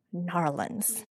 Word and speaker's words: New Orleans: We are here coming to you from ZeroCon, from what I say, New Orleans, but I've heard from my Uber driver from the airport to here that it's pronounced Narlins. So if you are here in New Orleans, say Narlins and you are good.